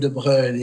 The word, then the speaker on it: de Broglie: Is it De Broglie? – Yeah, it's in French, it's called De Broglie, yes, but it's written De Broglie. It's written B-R-D, then it's B-R-O-G-L-I-E.